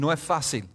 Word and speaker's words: no es fácil: Or if you're really Cuban, no es fácil. You don't pronounce the S.